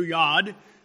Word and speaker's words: yod: Now, most of you have heard this, but just in case you haven't, the jot, it's pronounced in Hebrew yod,